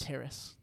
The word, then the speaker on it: Terrace: I thought his name was Terrence. – Terrace. – Terrace Marshall? – Terrace. – Are you serious? Oh, my God. I apologize to Terrace Marshall. I have not been saying his name properly.